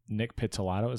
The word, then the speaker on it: Pizzolatto: And then Nick Pizzolatto, is that how you say his name? – Yeah.